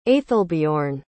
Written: aethelbeorn

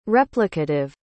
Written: replicative

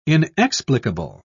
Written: inexplicable

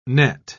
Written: nett

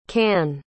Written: cannes

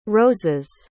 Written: rose's

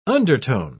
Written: undertone

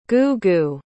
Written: goo-goo

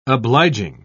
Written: obliging

